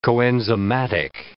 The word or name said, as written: coenzymatic